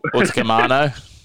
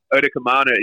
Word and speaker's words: Utakumano: And Big Stefano, can never say his name. – Utakumano. – Utakumano, yeah, rhymes. Stefano Utakumano.